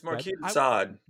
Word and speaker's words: Marquis de Sade: Is it Marquez de Sade? – It's Marquez de Sade.